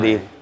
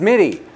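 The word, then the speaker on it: Mitte: And last but not least, another actor in the film, RJ Mike. – No one ever says his name right. – Did I say it wrong? – No, it's okay. – Oh. I'm sorry. – Mitty. – It's Mitty.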